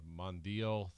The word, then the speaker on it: Mondeo: Then he drives away in a red Mondeo or Mondo? I'm not sure how you say it. – Is yeah, it's the Mondeo 3.2 Cabriolet.